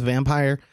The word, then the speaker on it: Vampyr: One recently, I think it's pronounced Vampire, V-A-M-P-Y-R, or Vampyr. I'm not really sure.